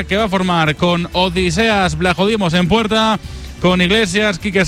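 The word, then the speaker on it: Odysseas Vlachodimos: Es Odiseas, realmente es, sí, el griego, sí, sí. Es Odiseas Blajodimos.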